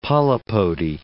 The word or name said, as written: polypody